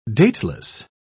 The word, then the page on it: dateless: British English